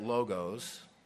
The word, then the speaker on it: logos: L-O-G-O-S. You might pronounce that logos. It's not, it's logos.